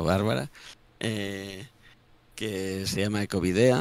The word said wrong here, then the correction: Ecovideac: ¿no? Si no lo digo mal. – Ecovideac. – Ecovideac.